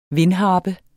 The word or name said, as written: vindharpe